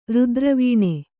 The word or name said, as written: ರುದ್ರವೀಣೆ